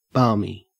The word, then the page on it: balmy: Australian English